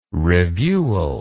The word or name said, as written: reviewal